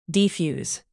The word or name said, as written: defuse